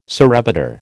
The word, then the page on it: surrebutter: American English